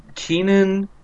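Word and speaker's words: Keiynan: Keenan, it's spelled weird. It's Keiy Nan Lonsdale. Keenan Lonsdale. Which, let me.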